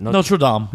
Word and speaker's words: Notre Dame: Notre Dame.